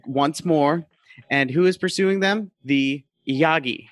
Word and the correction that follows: Unagi: Am I saying that right? – Unagi. – Unagi. – Who is following them? The Unagi. – No, it's funny that you keep saying it wrong.